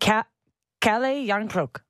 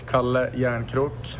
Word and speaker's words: Kalle Järnkrok: There's no lay. It's like Kalle. – Kalle. – Kalle Jarnkrok. – How do we get that end clip one more time? Can we actually have that as a drop? So anytime we have to talk about Jarnkrok, we just... – My name is Kalle Jarnkrok, and that's how we pronounce it.